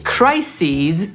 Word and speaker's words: crises: Bo jeżeli crises, to bardzo dobrze. Właśnie tak zmienia się to słowo w liczbie mnogiej. Po pierwsze, wymawiamy inaczej tę końcówkę, bo było crisis, a tu crises.